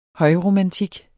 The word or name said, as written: højromantik